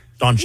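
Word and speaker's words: Dončić: Doncic. – You said it right? – Okay. Doncic. Because you know how it's spelled. – Yeah. – But anyway. – But you said it great.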